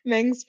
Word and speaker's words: Meng: So they go into Aunt Wu's dojo, I guess, and they meet her assistant named Meng. – Right. – Meng, okay. – Rhymes with Aang.